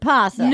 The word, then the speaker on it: opossum: It's called an opossum. – But you could pronounce it possum. – No, it's pronounced possum. Some people spell with an O, some people... – It's pronounced possum, but there's an O in front of it.